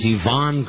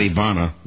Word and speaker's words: Yvonne: It's Yvonne Craig. – Not Ivana. – Ivana Craig. It's Ivana Trump and Yvonne Craig.